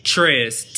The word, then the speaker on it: Trez: Trez. – Prince? – Trez. T-R-E-Z. – Trez. – Yes. – Trez. – Yes. – Like rhymes with Pez.